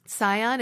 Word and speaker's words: Tsion: But uh, spell it for me, so I Tsion. – It's T S I O N, like Zion.